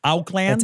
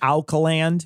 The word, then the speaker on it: Auckland: I went to Auckland. I don't know if I'm saying that correctly. I don't really care. But I believe it's Auckland. – It's Auckland. Yeah.